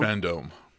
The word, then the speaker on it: Fandome: I said that right, Fandome? – Fandome. – Okay, Fandome. I'll give you one guess as to why they likely jumped the gun.